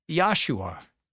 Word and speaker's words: Yahshua: Jesus is a Hebrew name. In Hebrew, it's pronounced Yahshua.